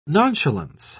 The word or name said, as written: nonchalance